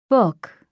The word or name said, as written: book